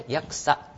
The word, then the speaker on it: 八色: 薬草の束ね、はい、これちょっと蛍光ペンお引きください。はい、天皇中心の身分秩序に皇族を編成した薬草の束ね、ね、はい、八色と書いてね、薬草と読みます。